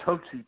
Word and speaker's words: Hotze: It's pronounced Hoetze, too, by the way.